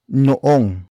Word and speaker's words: noong: The next word is noong.